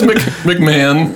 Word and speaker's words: McMahon: Gerard McMahon is the stage name for the real person, Gerard McMahanan. – No, it's McMahon. – McMahan. – Just a different spelling. – Oh, man. – The H is silent.